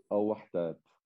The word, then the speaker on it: Al Wehdat: Okay, so you've now moved on to Al Wehdat Sport Club. Forgive my pronunciation. – Wehdat. Al Wehdat.